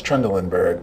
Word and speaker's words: Trendelenburg: I've heard other older physicians correct correct me when people will say Trind- Trendelenburg, and they say, no, it's Trendelenburg.